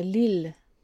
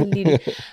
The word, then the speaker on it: Lille: Ich glaube, das war nach äh, Lille.